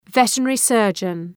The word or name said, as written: veterinary surgeon